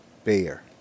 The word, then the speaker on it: Bexar: Tell me what this is. Bexar. What is this? How would you pronounce that? – Bexar? Or Bexar? – Bear. – Okay. So it's that like weird silent X or something? – When did the X become silent?